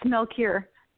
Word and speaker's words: Melchior: How do I pronounce it? Is it Melchior? Did I do it right? – It's Melchior. – Melchior. Okay. All right. I was close.